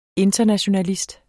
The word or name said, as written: internationalist